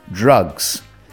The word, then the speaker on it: Drukqs: It is called Drukqs. Um, in fact, it's um, not spelt as the word drugs is. It's spelt D R U K Q S.